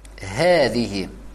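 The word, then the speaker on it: Хэзихи (هذه): «Хэзихи» читается все-таки с ударением. После буквы «хэ» есть маленькое ударение. «Хэзихи», то есть мы тянем фатху.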